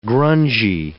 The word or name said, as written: grungy